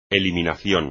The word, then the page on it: eliminación: espagnol